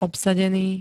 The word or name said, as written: obsadený